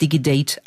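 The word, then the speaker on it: Digid8: Die heißt ähm, DigiDate, also Digit geschrieben und dann die 8, DigiDate ausgesprochen.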